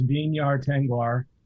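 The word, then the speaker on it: Vinyar Tengwar: So W is not pronounced V in Quenya. So it's Vinyar Tengvar and Tengvestie.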